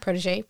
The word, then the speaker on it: Protégé: Protégé? Mm-hmm. Protégé? – Yeah. – You know, sometimes you try to say it very French. Very French. So, Kandice, we're talking about The Protégé.